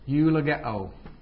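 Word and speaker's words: Eulogeo (εὐλογέω): You will see this in other places as well, but the word there for bless is actually eulogeo. E-U-L-O-G-E and then long O. And this is just pronounced like U. Eulogeo.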